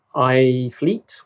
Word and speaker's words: iThlete: and I take it with an app called iFleet, which is spelled I-T-H-L-E-T-E, from a guy called Simon Wedgerith.